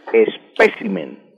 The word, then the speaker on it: espécimen: Don Gilberto, la palabra correcta es espécimen, espécimen con acento en la segunda E, espécimen, y no espécimen.